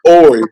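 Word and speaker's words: Horry: Kiva is a mother and former wife of seven-time NBA basketball player Robert Ori, the H is silent.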